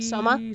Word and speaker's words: Soma: What's his name? Soko Suki? – Soma. – Soma, thank you. – Yeah, Soma Yukihira. – I got most of those syllables right, just in the wrong order.